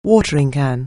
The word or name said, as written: watering can